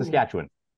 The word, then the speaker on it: Saskatchewan: How do you pronounce Saskatchewan? – Ooh. Saskatchewan. – Nailed it. First try. – That was a tweener. – No, that was spot on.